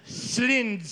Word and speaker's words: Céline: I heard someone say Celine Dion, but I heard them pronounce it the French way. Céline Dion. – Excuse us, Celine Dion? Céline. Celine Dion? Céline Dion. It is not Celine Dion.